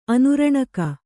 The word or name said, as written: ಅನುರಣಕ